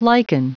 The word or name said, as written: liken